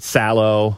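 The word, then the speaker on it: Salò: So we're starting to get those, you know, the extreme scenes, of course, the entire Serbian film, and then, you know, that Sallow. Is that how you pronounce that? Yeah, the Marquis de Sade thing.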